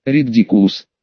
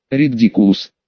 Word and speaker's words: Ридикулус: Заклинание произносится так. Люпин встал перед учениками и, взмахнув палочкой, произнес. Ридикулус. Ридикулус. Еще раз. Ридикулус.